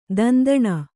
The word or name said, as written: ದಂಧನೆ